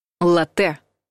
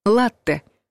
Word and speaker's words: латте: Слово «латте» обычно пишут правильно, с двумя буквами «т», потому что мы будто бы и произносим удвоенный твердый звук «т», а вот с ударением беда. Верно ставить его на первый слог.